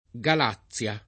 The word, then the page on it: Galazia: lingua italiana